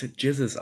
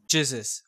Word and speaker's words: Gzuz: Man spricht es Jizzes aus. Jizzes.